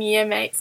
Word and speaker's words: Yamate: and the closest station is Yamate, which is spelled Y-A-M-A-T-E. So he says the easiest way to remember it is that it looks like your mate.